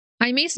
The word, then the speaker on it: Jaime: Jaime's name is spelt like Jamie, but I believe it is pronounced as Jaime.